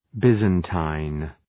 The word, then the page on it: Byzantine: ‘bızən,ti:n; ‘bızən,taın